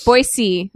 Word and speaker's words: Boise: There's no Z in Boise. Yes, Boise is how you say it. It's French.